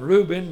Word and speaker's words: Reuven: And the president at the present time is a fellow by the name of, I would call him Reuben, they spell it R-E-U-V-E-N, his first name, Rivlin, R-I-V-L-I-N.